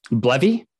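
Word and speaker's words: BLEVE: Blevy? – Yeah. – It's B-L-E-V-E. So it's an acronym.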